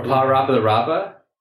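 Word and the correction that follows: PaRappa the Rapper: How are you pronouncing that? – Pa Rapa the Rapa? How do you pronounce it? – A Rapper the Rapper. – Oh, okay. – Because he's a rapper.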